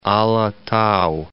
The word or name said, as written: Ala Tau